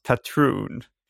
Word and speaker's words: Patreon: Att uh, Patreon uh, eh, uttalas Patreon.